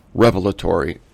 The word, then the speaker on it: revelatory: I know that properly it's revelatory, but I like to mix up the accents on odd syllables.